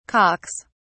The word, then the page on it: cocks: British English